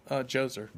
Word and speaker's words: Djoser: How do you say that? Is it Djoser or Djoser? – Djoser.